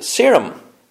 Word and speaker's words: Seirm: Yeah, he was recently featured on BBC Alba's show Serum, or I've heard it pronounced like 100 different ways, but it's spelt S-E-I-R-M, so Serum, and so that's how I'm pronouncing it.